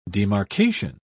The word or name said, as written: demarcation